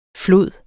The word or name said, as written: flod